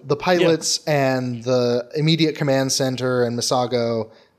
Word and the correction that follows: Misato: I'm probably mispronouncing yeah, that, even though I just Misato. There we Misato.